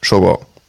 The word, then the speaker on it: Shogun: Ganz kurz, das heißt Shogun.